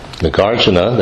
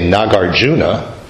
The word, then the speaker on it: Nagarjuna: Nagarjuna, that's the actual Sanskrit pronunciation. It ends in two short syllables, Nagarjuna. Mm-hmm. Now, I hear somebody say Nagarjuna, and that sounds terrible to me. But I can understand that they're saying Nagarjuna. But in my mind, the sound of that name is represented by Nagarjuna. The accent is on the second syllable, not at the third syllable.